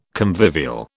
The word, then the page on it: convivial: British English